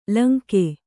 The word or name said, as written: ಲುಂಗ